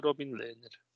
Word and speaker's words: Robin Lehner: Das ist richtig gesagt, heißt der Robin Lehner.